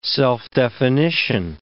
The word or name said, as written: self-definition